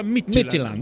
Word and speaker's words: Midtjylland: Ho letto ieri che si pronuncia Midtjylland.